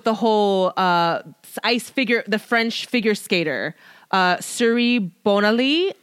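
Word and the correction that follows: Surya Bonaly: oh my, which I probably completely butchered her name. Mm-hmm. I'm so sorry. It's Suraya Bonaly.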